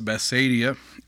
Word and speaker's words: Bethsaida: Mark 8, verses 22 through 26 says, "And he, Jesus, cometh to Bethsaida," or some pronounce it Bethsaida, "and they bring a blind man unto him, and besought him to touch him.